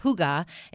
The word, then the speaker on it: Hygge: It's Huga, but it's spelled Hygge. It's H-Y-G-G-E, and it's pronounced Huga, and it is a Scandinavian game about cozy conversation in pleasant company.